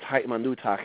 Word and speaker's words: ha'imanutach: That word ha'imanutach, in this case, it's ha'imanutach. It's got the kuh sound at the end because it's possessive. It's yours, he was saying.